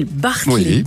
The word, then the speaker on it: Bartleby: Ça s'appelle Bartleby, prononce. Ça s'appelle Bartleby.